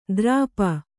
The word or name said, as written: ದ್ರಾಪ